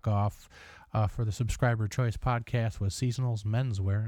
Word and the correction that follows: Seasonal Menswear: and I keep pronouncing that with a plural S. Sorry, it's my South Side upbringing. We pluralize everything. Jewels, Kmart's, what what have you. – Uh, so Seasonal Menswear, that's better.